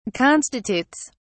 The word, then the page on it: constitutes: British English